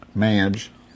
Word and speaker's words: Madge: Madge. M-A-D-G-E. – Can you spell that for me? Yeah. M-A-D-G-E?